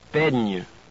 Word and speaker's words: peigne: Next, the letters G and N are pronounced somewhat like our NY in canyon or NI in senior. Listen to these. Montagne. Baigner. Peigne.